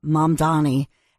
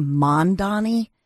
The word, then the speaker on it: Mamdani: Well, I think first and foremost, if you look at Zohran Mamdani, and by the way, Cuomo kept saying Mondani, like M-O-N, and then... And that, I guess that triggered people, and especially the candidate, because he would say, my name's pronounced Mont, like M-A-M, Donnie.